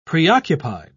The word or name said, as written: preoccupied